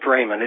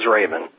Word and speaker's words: Ramon: No, it's pronounced Raymond. It just looks like that, but it is Raymond.